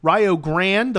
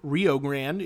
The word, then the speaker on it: Rio Grande: So Midway is the 1, Point Park is the 2, Indiana Southeast the 3, Indiana Kokomo the 4, Rio Grande the 5, or where I'm from, Rio Grande is the 5 seed.